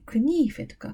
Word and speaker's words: knife: で、中世英語といってね、まあ、荒っぽく言うと 1100 年から 1500 年くらいの頃を言うんだけれども、この頃にちゃんと発音されてたらしいんです。で、綴りもね、 CNIFE でクニーフェとか、あと E がつかないでクニーフなんていう綴りは残っています。